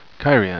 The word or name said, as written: cairhein